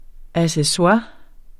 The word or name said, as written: ACCESSOIRES